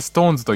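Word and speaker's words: SixTONES: シックストーンズと書いて「ストーンズと読むんだ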